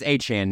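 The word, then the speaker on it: Achane: I know people have talked about, how do you say his name, the rookie running back, Achan? – I think it's Achan now, yeah.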